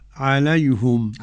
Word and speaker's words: عليهم: ميم جمع أنفسهم يتلو عليهم، ضم الهاء من عليهم حمزة رضي الله عنه ويعقوب الحضرمي. – تنطق كيف فضيلة الشيخ؟ – عليهم. – عليهم. – طيب نجيب دليلها من الشاطبية لحمزة. عليهم إليهم حمزة ولديهم جميعا بضم الهاء وقفا وموصلا.